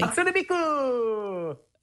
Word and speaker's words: Hatsune Miku: Hatsune Miku!